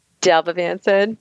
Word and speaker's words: Dalbavancin: I think Aritavancin and Dalbavancin.